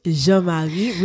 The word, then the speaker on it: Jean-Marie: I never get to hear my name pronounced properly, so I appreciate you for that. Oh, you and will. I purposely say Jean Marie because I'm not going to sit for 20 minutes explaining to someone how to say it properly.